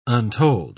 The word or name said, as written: untold